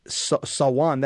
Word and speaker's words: Samhain: Yeah, I think it's pronounced it's Sawan.